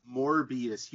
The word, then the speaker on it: Morbius: it's pronounced Morbius. You keep Morbius. – saying Morpheus.